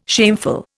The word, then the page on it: shameful: Indian